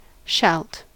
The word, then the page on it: shalt: American English